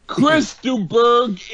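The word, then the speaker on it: Chris de Burgh: Chris DeBerg is the right pronunciation.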